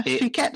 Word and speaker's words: Phuket: And there was Phuket. Phuket, that's how you pronounce it.